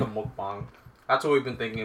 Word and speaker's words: mukbang: Then my other Korean friend is like, you're an idiot. It's mukbang. We're clearly Asian. We have the short A sound.